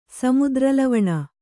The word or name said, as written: ಸಾಮುದ್ರಲವಣ